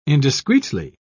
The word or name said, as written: indiscreetly